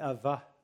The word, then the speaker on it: Abba: And we have the privilege of addressing God our Father by the ancient Aramaic term of Abba, or more correctly, Ava. They pronounce it as a soft B. It's almost a V. Ava.